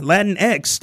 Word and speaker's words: Latinx: But when it's a lowercase x, you got to pronounce it Latinx.